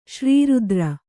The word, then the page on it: ಶ್ರೀರುದ್ರ: kannada